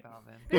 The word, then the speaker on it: Belvin: Belvin.